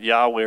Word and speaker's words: Yahweh: Okay, sometimes we pronounce it Yahweh or Jehovah.